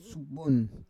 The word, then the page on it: sub buon: Roon